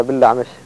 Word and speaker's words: باللعمش: الجزائريون ينطقون هكذا باللعمش أي ابن الأعمش، هذا اسمه شهرته هكذا.